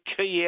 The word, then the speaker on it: quay: How do you get key out of Q-U-A-Y? – You know what?